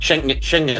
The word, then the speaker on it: Schengen: Schengen.